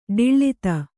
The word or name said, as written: ಡಿಳ್ಳಿತ